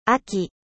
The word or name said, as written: 秋